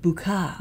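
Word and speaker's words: Boucart: But it's B-O-U-C-A-R-T. – Yes. – And you pronounce it Boucar.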